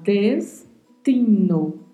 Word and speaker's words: destino: Primeiro porque sabemos que não tem som de di no espanhol e nem de ti. Então vamos falar destino. Esta é a forma correta de falar.